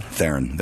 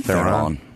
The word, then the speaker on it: Theron: You still said it wrong, though. – Theron? – Theron. There you go. – What'd I say? – Theron.